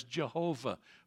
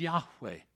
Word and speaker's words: Yahweh: It's been Germanicized coming into the English language, so you've probably heard him referred to as Jehovah from time to time, but there is no J or V in the Hebrew alphabet. It would have been impossible for them to pronounce it that way. His name is Yahweh.